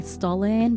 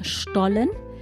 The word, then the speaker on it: Stollen: Maybe some English speakers would say Stollen, but it is pronounced Stollen.